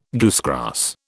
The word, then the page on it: goosegrass: British English